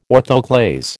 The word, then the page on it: orthoclase: American English